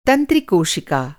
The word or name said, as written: तंत्रिकोशिका